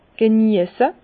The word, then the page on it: geniesse: Bas-Rhin; Herrlisheim